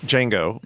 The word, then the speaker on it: Django: The D is silent. – Django, which is crazy violent.